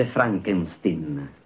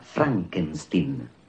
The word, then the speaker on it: Frankenstein: Io avrei una domanda, dottor Frankenstein. – Si legge Frankenstein. – Come, prego? – Il mio nome si pronuncia Frankenstein.